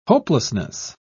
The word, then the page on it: hopelessness: British English